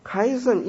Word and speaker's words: chiasm: chiasm 英文是叫 chiasm， chi 其实不是读成 chi，是读成 chi，因为它其实是希腊的一个字母 chi，就是一个打叉读成 chi， chi chi，就好像我们化学 chemistry CH 就读成 K 的读音，所以 chi CHI 是一个打叉，在希腊文是个打叉的字。